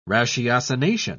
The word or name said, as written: ratiocination